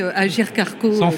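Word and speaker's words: Agirc-Arrco: Alors déjà, bravo d'avoir réussi à prononcer Agir Carco correctement.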